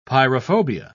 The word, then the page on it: pyrophobia: British English